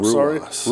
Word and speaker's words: Ruas: The first match that kicked off the second hour was Pete Dunne, Bruiserweight, against Arturo, if I pronounce this wrong, Ruas. All right, so you pronounce like Shogun Rua.